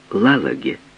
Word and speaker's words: Лалаге: «Как ее зовут?» «Лалаге». Она произнесла это необычное имя с ударением на первом слоге, по-прежнему глядя в пол.